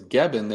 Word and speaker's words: Gebben: And when she said that her maiden name was Gebben, they both kind of looked at each other and said, "Gebben, that's a really cool name."